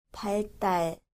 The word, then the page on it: 발달: baldal